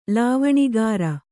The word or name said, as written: ಲಾವಣಿಕಾರ